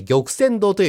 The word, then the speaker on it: 玉川堂: この工場の祭典に参加する企業の中から、燕市の玉泉堂、えー、玉川堂と書いて玉泉堂と読みます。